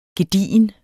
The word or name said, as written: gedigen